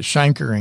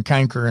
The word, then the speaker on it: chancre: And I've heard people pronounce that chancre and canker, and I think there's multiple ways to do it, but it's basically a painless red sore.